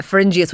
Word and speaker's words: pharyngeus: Are we worried about the fella who has globus faragius or faragius? – I think this man, in fairness to you, George, it's spelt Always, but it is. I think he means he has globus pharyngeus, which used to be called globus hysterigus.